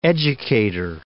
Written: educator

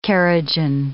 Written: kerogen

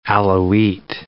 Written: Alawite

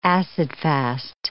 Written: acid-fast

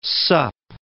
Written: sup

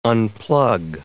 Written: unplug

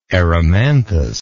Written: Erimanthus

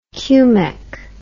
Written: cumec

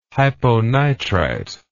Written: hyponitrite

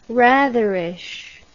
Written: ratherish

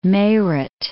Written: Meerut

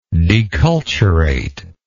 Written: deculturate

